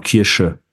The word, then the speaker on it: Kirsche: Ist es Kirche? ist es da etwa Kirche? Oder wie, wie, spricht man das richtig aus? Es gibt Glaubens und äh, die, die, das so zum Essen. Kirche ist zum Essen, ne? Kirche, ja. Genau. SCH-Kirsche. Genau, Kirsche, genau.